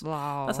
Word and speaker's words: Laos: I, in my head, pronounce it Laos. – Laos. – Laos. – That sounds good.